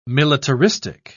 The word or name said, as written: militaristic